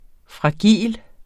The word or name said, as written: fragil